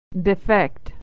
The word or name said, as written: to defect